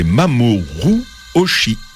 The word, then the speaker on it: Mamoru Oshii: Kenji Kawai a signé la musique de ce film de Mamoru Oshii. Je suis en train d'écorcher son nom. C'est Mamoru Oshii.